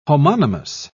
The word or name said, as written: homonymous